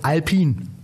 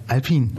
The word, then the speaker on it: Alpine: Die Marke spricht man aus Alpine. Geschrieben Alpine, aber das E ist stumm. Also wir sind hier bei Alpine.